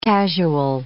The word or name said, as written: casual